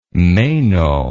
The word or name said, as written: meno